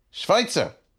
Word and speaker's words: Schweitzer: Schweitzer.